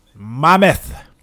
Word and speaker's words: Monmouth: I think it's pronounced Monmouth. – Mammoth. – Mammoth? – Yeah, like almost like a Willy Mammoth, but Mammoth. Like Mama.